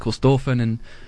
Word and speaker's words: Corstorphine: So yeah, someone was saying that it's actually pronounced Corstorphine in Scotland and where it's originally from, and we've just Dunedinised it.